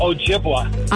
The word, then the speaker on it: Ojibwa: Hi, I'm pretty sure the Indian nation that you're trying to pronounce is pronounced Ojibwa. – I'm almost positive you're right. Ojibwa.